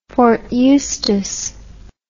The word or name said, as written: fort eustis